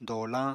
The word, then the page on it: doolañ: apatani